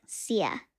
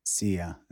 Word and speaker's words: Szia: But it's spelled like S-Z-I-A. – S-Z-I-A. And you pronounce it like Zia? – Yeah, like Sia. – Sia.